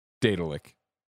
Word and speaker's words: Daedalic: At this point, Google's pronunciation of Daedalus is, Daedalus. So then it would be Daedalic.